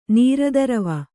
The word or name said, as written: ನೀರದರವ